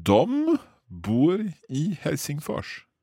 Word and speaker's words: de: Because it's D-E when it's written down, but we pronounce it dom. – Yes, because otherwise you sound like you come from the 1800s. – Okay. So dom bor I Helsingfors.